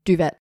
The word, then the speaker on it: duvet: Because you don't pronounce the T, so... – Yeah, and so then convicts got sent over. Or was I meant to say it as duvet? – No, it's duvet.